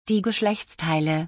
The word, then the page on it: die Geschlechtsteile: German